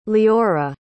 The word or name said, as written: liora